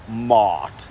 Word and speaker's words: Walmart: Walmart is how the British would say it. Phonetically, it would be W-A-W-L, wool, and then the second word, M-O-T-T, mot, Walmart. Okay? – Rollback prices, baby.